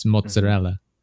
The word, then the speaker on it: mozzarella: I'm like, in Italy, they use the A because that's how it's spelled. It's mozzarella. And yeah, stuff like that.